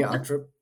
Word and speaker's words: Ochtrup: Hi, Heidi Ochtrup. Did I say your name right now that since we've known you for years a couple years now? – Ochtrup. You did very well.